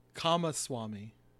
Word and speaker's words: Kamaswami: But uh, even Kami Wasami, uh, that, shit. Uh, Ka- How do you say that? Kamaswami.